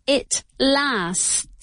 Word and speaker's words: lasts: Lo que quiero decir es que en tercera persona se pronuncia así. It lasts. It lasts. Tenemos una S, una T y otra S. Por eso es un poco complicado.